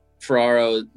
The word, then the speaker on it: Ferraro: Correct me if I'm wrong, but you're a big – Mario Ferraro guy, I feel like. Ferraro, oh, yeah. – I don't say his name. – Ferraro, yeah, he's my guy.